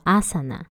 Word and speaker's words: asana: 今天的题目是阿萨那体位法。你知道瑜伽我们常做的这些动作，它的梵语原文叫做什么吗？叫做阿萨那。